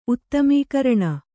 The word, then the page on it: ಉತ್ತಮೀಕರಣ: uttamīkaraṇa